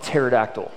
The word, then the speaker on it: pterodactyl: Like the number of silent letters, who puts a P on the front of pterodactyl?